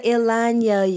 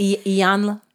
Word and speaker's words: Iyanla: And I ain't got to Ilanya you. – Oh, did I say her name right? – Iyanla.